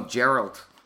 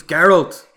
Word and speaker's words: Geralt: Some people call him Geralt, but it's Geralt.